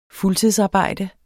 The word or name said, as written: fuldtidsarbejde